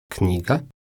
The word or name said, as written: knjiga